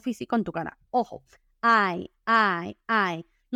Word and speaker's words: eye: Entonces, ojo, hay obviamente ojos, eyes, eyes, se escribe e y -E, pero tiene una pronunciación bastante particular, lo siento, se pronuncia ay, como si lo estuvieran pellizcando a uno, ay, ay, ay, ay.